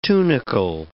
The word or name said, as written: tunicle